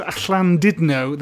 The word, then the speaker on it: Llandudno: Mike, how is Llandudno this evening? Is it, that's how you pronounce it. – Well pronounced, well pronounced.